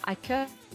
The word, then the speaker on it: hacker: Теперь дальше вспоминаем, что «h» во французском языке не читается, и берем слово «хакер» и читаем его по-французски, и получаем прекрасное французское слово «hacker», которое и означает вполне официально вот этого вот самого хакера.